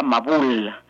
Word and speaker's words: mabul: La palabra diluvio en hebreo se pronuncia mabul.